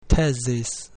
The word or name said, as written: thesis